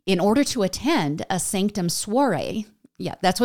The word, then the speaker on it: SNCTM: Okay, I didn't know about this series, and Sanctum is spelled in all capital letters, and there's no vowels, so it's just SNCTM, so Naked Sanctum.